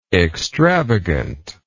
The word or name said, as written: extravagantness